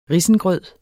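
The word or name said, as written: risengrød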